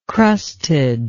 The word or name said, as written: crusted